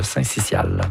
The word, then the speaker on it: syncytial: Non, selon le dictionnaire Usito, on prononce le syncytial. – Voilà.